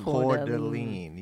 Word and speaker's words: Coeur d'Alene: Coeur d'Alene, yes.